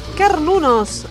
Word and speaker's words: Cernunnos: Muy bien, estamos escuchando Carnunos. ¿Lo dije bien? – Correcto. – Ahí está, es correcto. Carnunos es una banda porteña,